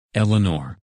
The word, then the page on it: Eleanor: English